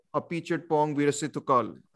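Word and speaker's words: Apichatpong Weerasethakul: Then there's Night Colonies by Appi Chitpong Virasithukal. I hope I pronounced his name correctly. – Yeah, you did.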